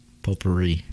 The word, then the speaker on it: potpourri: P-O-T. – No, this is different. This pot is potpourri. – This is P-O-P-U-R-R-I. Potpourri. – Ray culture. – This potpourri has silent letters in it.